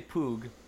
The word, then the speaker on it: Puig: My name is Jose Puig, and I work as a journalist for a...